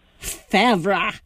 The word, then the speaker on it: Favre: That would be Brett Favre. – Favre. Yes. You are correct, and you even pronounced it the way it's spelled.